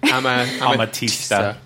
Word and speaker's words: Amatista: Amatista.